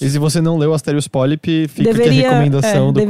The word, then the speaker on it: Asterios Polyp: E eu recomendo que, se você gostou muito de Asterios Polyp, que eu não sei pronunciar direito. – Eu sempre falei Asterios Polyp, eu acho que é assim mesmo.